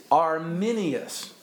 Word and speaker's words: Arminius: It is Arminius, not Arminius.